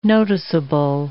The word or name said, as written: noticeable